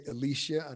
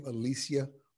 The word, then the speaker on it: Alycia: So, but I meant to say Alycia. I know how to pronounce her name, Alycia Baumgardner.